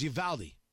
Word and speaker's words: Uvalde: It was Uvalde. Al, not ah, Al. I was listening to him pronounce it, so I want to say it right.